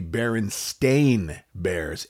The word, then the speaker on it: Berenstain: Many people remember Berenstein or Berenstein Bears, spelled the last syllable S-T-E-I-N. – It's Berenstein Bears. – Well, the truth is that their last name is actually Berenstain Bears, S-T-A-I-N, Berenstain Bears. It was never Berenstein or Berenstein Bears, ever, nor was it ever spelled S-T-E-I-N.